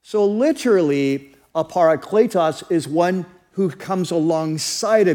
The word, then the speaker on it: parakletos: So the word is parakletos, parakletos. And here's how you spell it. It's P-A-R-A, para. And then the second is kletos, K-L-E-T-O-S, parakletos.